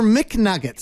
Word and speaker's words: Macnuggits: Thank you, Nerd Now, is it Nuggets or McNuggets? Some people get angry about the way it's pronounced, don't they? – Yeah, it's Mac.